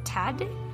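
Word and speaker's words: Tadić: AX defeated rivals AEK Athens 2-0 with a brace from Tadic? – Yeah.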